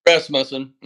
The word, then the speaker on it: Rasmussen: Rasmussen.